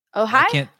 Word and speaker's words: Ojai: Ojai? O-J-A-I. Ojai? – Ojai? – Ojai? – I can't see it, but it's probably, sure.